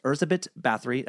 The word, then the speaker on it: Erzsébet Báthory: Countess Erzsébet Báthory. That's the Hungarian pronunciation, as best as I can do, written by Kimberly Kraft.